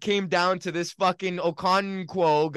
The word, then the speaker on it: Okonkwo: How do you say that? – Don't I have Okonwu? – Chigozem Okonkwo.